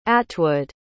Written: atwood

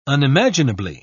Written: unimaginably

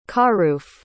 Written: kharouf

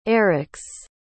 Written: Erix

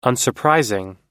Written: unsurprising

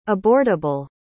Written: abortable

